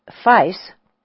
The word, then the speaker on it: Fais: Our Tibetan guide, Fais, rhymes with nice, he explains, says that the uniformed, sockless boy in the seat next to him is the assistant bus driver.